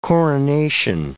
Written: coronation

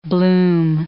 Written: bloom

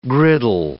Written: griddle

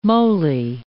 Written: moly

